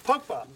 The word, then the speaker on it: Pogba: Pogba.